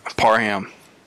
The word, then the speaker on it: Parham: Parham. – It's Parham. Literally how it sounds.